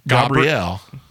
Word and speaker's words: Gabriel: That's how it's pronounced in Hebrew. Gabriel.